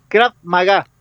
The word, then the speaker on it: Krav Maga: Bueno, primero, ¿hice bien la pronunciación? ¿Es Krav Maga? – Sí, es Krav Maga.